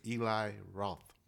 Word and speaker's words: Eli Roth: But the director that's attached to this movie is Eli Roth.